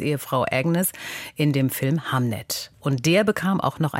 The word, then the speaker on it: Hamnet: Katja, vielleicht sollten wir uns erstmal der Aussprache widmen. Der Film heißt Hamnet, wie der Sohn von William Shakespeare, und nicht Hamlet, wie das Drama von Shakespeare.